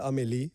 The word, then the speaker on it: Amélie: Emeli ya da Doğru bir telaffuzla. E, Fransızca'da Amélie, Hı-hı. – Amélie e, diye geçiyor. Hani İngilizler Emily, Emily diye biliyorlar.